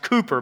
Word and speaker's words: Cowper: Actually, it's pronounced Cooper, but it's spelled Cowper.